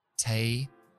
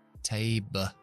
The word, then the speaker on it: table: Y son un poco especiales porque en realidad la E al final nunca se pronuncia y ya sabéis que lo que dijimos al principio, una sílaba siempre tiene que contener una vocal, el sonido de una vocal. Entonces se ponen como una pequeña schwa entre lo que es la última consonante y la L. Por ejemplo, mesa es table. Ahí está la schwa. Table. Table. Table.